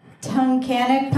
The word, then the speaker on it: Tunkhannock: His legendary short film, Jerky, caused waves all over Tunkhannock, Pennsylvania. – Oh, I loved your pronunciation of Tunkhannock. – Did I say it wrong? – No, it was perfect.